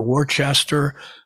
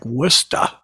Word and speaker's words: Worcester: I had no idea how you pronounce W-O-R-C-E-S-T-E-R. And I thought it was either Worcester or Worcester, but people confirmed that it's actually pronounced Worcester. In fact, some of them pronounce it almost like Wista, but I call it Worcester.